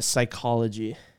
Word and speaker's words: psychology: You know which one I always mess up is psychology. – So you're always missing those silent P's.